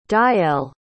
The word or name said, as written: deijelle